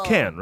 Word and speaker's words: Cannes: Cannes Film Festival. – Wow. – It's Cannes, right? – Yeah. – Yes, okay. It's pronounced Cannes.